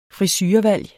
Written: frisurevalg

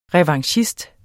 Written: revanchist

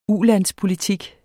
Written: ulandspolitik

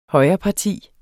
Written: højreparti

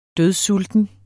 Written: dødsulten

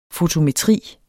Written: fotometri

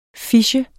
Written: phishe

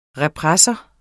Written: repressor

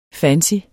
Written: fancy

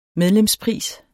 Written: medlemspris

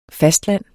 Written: fastland

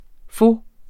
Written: pho

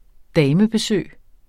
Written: damebesøg

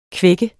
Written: kvække

